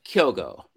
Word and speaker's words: Kyogo: So it's just Kyogo, and it's just Kyogo, and it's just two syllables, Kyogo.